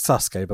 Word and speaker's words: Sasuke: We had the Great Sasuke, Gran Hamada, and Gran Naniwa. – Yeah. It's Sasuke, by the way, but it's okay, yeah. – Oh, Sasuke. – Yeah, just don't pronounce the U, it's fine.